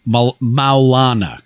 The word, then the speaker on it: Maulana: But Milana Karenga is, or Maulana Karenga. I'm sorry. I should pronounce that better, even if he's an asshole. I should still pronounce African names properly.